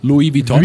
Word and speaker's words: Vuitton: No, I don't pronounce the U. It's Louis Vuitton.